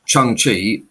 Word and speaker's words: Shang-Chi: So after uh, Black Widow on September 23rd, I am not uh, reading this from memory, by the way, um, is Shang-Chi, I believe it's pronounced now, and The Legend of the Ten Rings.